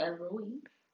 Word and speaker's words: Elohim: Elohim.